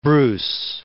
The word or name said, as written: Bruce